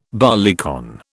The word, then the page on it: barleycorn: British English